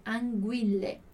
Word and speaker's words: anguille: Mas enquanto no italiano, em anguille, anguille.